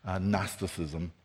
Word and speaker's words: Gnosticism: The last thing John would want us to know is that he's writing against an early heresy called Gnosticism, and that's one of those weird English words with a G in front of it, a silent G, Gnosticism.